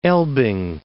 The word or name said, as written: Elbing